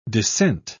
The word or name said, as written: descent